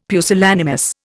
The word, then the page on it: pusillanimous: Indian